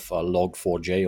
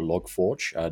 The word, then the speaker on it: Log4j: we need to get an understanding of Log4j or LogForge, depending on your pronunciation.